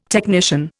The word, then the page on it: technician: Indian